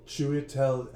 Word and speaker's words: Chiwetel: Like I said, I could talk about how it stars Clive Owens, Julianne Moore, Michael Caine, I can't pronounce his name, but Chidiwell Ejiofor? – Chiwetel Ejiofor, I think.